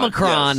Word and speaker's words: Omicron: I think it's Omicron. – Omicron. – Yes, that's how I've heard it pronounced.